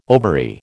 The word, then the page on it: ovary: American English